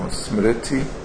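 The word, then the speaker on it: smṛti: In Sanskrit, smriti, the R with a dot under is pronounced as if there's an I after the R, so smriti.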